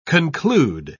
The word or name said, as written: conclude